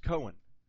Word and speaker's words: Cohen: We actually use the name later, Cohen, C-O-H-N, or C-A-H-N, or C-O-H-N. But it's pronounced Cohen.